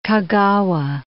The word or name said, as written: Kagawa